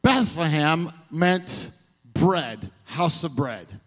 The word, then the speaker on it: Bethlehem: The word Bethlehem, Bethlehem is how you say it in Hebrew, Bethlehem.